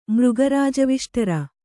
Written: ಮೃಗರಾಜವಿಷ್ಟರ

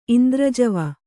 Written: ಇಂದ್ರಜವಿ

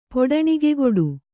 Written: ಪೊಡಣಿಗೆಗೊಡು

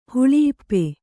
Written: ಹೊಳೆಇಪ್ಪೆ

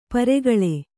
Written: ಪರಕಾಲ

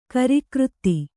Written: ಕಾರಕಿರಿದಿ

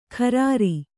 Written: ಖರಾರಿ

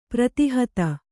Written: ಪ್ರತಿಹತ

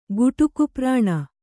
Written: ಗುಟುಕುಪ್ರಾಣ

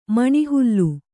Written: ಮೇಣಿಹಾಲು

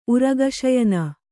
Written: ಉರಗಶಯನ

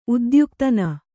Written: ಉದ್ಯುಕ್ತನ

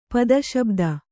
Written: ಪದಶಬ್ದ

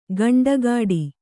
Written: ಖಂಡಗಟ್ಟು

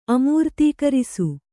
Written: ಅಮೂರ್ತೀಕರಿಸು

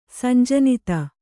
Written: ಸಂಜನಿತ

